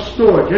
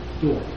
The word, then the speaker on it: storge: Called storge, S-T-O-R-G, storge.